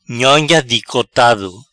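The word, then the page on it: Nhonha dicotado: Patua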